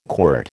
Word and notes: quirt: American English

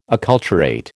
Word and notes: acculturate: American English

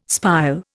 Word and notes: spile: Indian

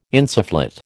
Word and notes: insufflate: American English

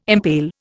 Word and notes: impale: Indian

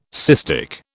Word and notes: cystic: British English

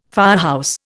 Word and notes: firehouse: Indian

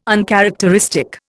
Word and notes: uncharacteristic: Indian

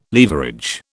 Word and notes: leverage: British English

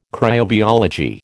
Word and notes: cryobiology: American English

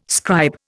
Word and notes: scribe: Indian

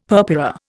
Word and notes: purpura: Indian

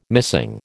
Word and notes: missing: American English

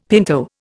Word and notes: pinto: Indian

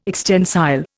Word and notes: extensile: Indian

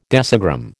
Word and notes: decigram: American English

